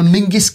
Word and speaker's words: Menzies: And in 1967, he lost a 100-meter race against British record holder and future Lib Dems leader, Sir Mingus Campbell. Do you remember him being the merciless? It's spelled Menzies, but it is pronounced Ming.